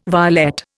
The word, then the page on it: violet: Indian